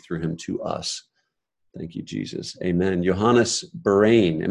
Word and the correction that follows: Berhane: It's actually pronounced Berhane. – Oh, Berhane.